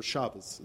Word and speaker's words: Shabbos: or Shabbos, as it's pronounced in Yiddish.